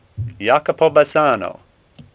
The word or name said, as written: Bassano